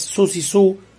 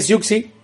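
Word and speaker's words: Siouxsie Sioux: Que yo de hecho me enteré que además de ella, que es Susie Sue, como se pronuncia, toda la vida dije Siuxi.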